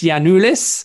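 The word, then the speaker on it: Giannoulis: In fact, this week, it's basically the second part of last week's because on Tuesday, Norwich confirmed the signing of Dimitris Yiannoulis. Don't pronounce the G, but don't tell Daniel Farker.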